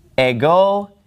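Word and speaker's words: Ego (ἐγώ): Okay, so legi afto o Iesus, ego imi iodos. This means I am the way. Now, the word ego, it gets the accent on the second syllable. See, that accent is over the omega.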